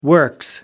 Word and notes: works: British English

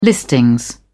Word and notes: listing: British English

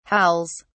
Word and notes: howls: British English

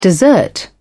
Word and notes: dessert: British English

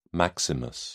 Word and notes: maximus: British English

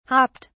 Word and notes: hopped: British English

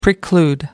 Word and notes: preclude: American English

